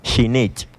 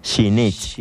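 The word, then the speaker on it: Xinich': Shinich. – Shinich. – Lo estás haciendo muy bien. Nuevamente, Shinich. – Shinich. – Excelente. – Rapidito, X-I-N-I-C-H y al término es apóstrofe.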